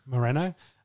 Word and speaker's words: Moreno: Is it Moreno? Have I got that right? – Moreno? – Moreno.